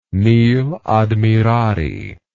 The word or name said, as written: nil admirari